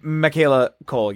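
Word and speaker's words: Michaela Coel: Oh, Michaela Cole, yes.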